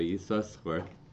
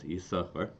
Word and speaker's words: Yisaschor: And it is pronounced that way, Yisaschor, even though usually that name is pronounced Yisachor.